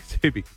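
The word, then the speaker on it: Ksubi: We were talking, mate of mine and I were chatting yesterday, because you know how we're debating how to say that fashion brand. – Oh, Subi. – Subi. – Kasubi. – Kasubi. – With a silent K.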